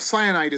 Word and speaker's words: Cyanite: So Cyanite is how it's pronounced in the audiobook. I had to look that one up because I assumed it was like the color, but no, I assume it's another stone since everyone at the, what is it called?